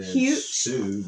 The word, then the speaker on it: Hsu: If you heard the last show, Stephanie Hsu, is that how you say it? – I said Sue.